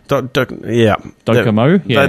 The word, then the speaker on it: Docomo: But it was a partnership, I think, Telstra had with Japan's Docomo? Is that how you pronounce it? – Docomo, yeah.